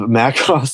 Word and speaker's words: Macross: Macross? Is that how you say it? – You got it.